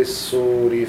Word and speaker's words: الصور: في الصور على طول لا تنطق اللام في الصور في الصور فتأتون أفواجا